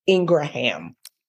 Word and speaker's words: Ingraham: And apparently the correct pronunciation is Ingraham.